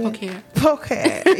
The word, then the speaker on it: Pulchr: So was it Pulchr? I don't want to say it wrong. Say the company name. – Pulchr.